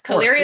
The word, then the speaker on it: Caliri: Heather Caliri, it's Caliri, right? I'm saying that right? – Yes, Caliri is correct.